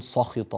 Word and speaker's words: سخط: فلا نقول سخط ص لا س س سخط الله عليهم، هكذا نتنبه لنطق الحروف ونعطيها حقها.